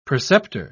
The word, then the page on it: preceptor: British English